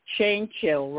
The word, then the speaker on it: Jainchill: My guest today is Nancy Jainchill, right? – Yep, said it right.